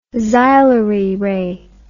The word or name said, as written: xylary ray